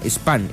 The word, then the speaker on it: Spahn: Karina Spam. Spam se escribe S-P-A-H-N.